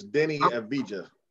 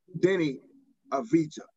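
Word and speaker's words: Deni Avdija: He's butchering his name. It's Denny Avija. – Denny Avija.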